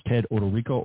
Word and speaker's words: Ted Odorico: And again, my name is Ted Odorico, O-D-O-R-I-C-O is how you correctly spell it.